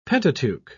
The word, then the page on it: pentateuch: British English